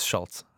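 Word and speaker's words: Schulz: The Andrew Schall's like. – Andrew Schultz? – Yeah, Andrew Schultz. What was I saying it wrong? – Schultz. – Isn't it Schultz? – It's Schultz.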